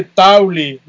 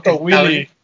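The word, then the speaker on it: Towelie: Se dice Tauli, no Willy. si ves ese programa, no me regañes a mí porque yo digo toallín. – Es Tauli, no Willy, pero bueno, ese yo apenas lo estrené y la verdad está bien bonito.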